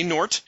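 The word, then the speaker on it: Gnort: I asked J.M. DeMatteis how to say Nort. He says the G is silent.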